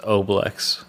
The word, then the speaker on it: Oblex: It is actually pronounced Oblex.